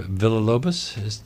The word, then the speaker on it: Villa-Lobos: The first piece you're going to play is by Heitor Villa-Lobos. Did I pronounce his name right? – I believe so.